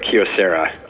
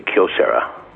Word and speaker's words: Kyocera: Is it Kyocera or Kyocera? – If you're Japanese, you say Kyocera, Kyo being one syllable. So try to say Kyocera, and you've got it.